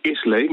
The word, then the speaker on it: Islay: Dat schrijf je Islay, maar dat spreek je uit Isla.